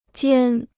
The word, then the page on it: 尖: jiān